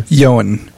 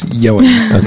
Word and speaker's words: Ioan: Also coming out in theaters, Amazing Grace, starring a guy whose first name is spelled I-O-A-N. – Yoan. – Oh, there you go. See, I thought it was Lone with a typo. – It's Yoan.